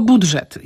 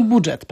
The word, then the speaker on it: budżet: Wymawiamy tylko budżet. Inaczej mówiąc, nie mówimy budżet, tylko budżet. A ewentualnie możemy mniej starannie powiedzieć budżet, czyli tam jest dżyży, ale najpoprawniej jest dyży, czyli budżet.